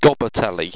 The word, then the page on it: gobatelly: Owstwecian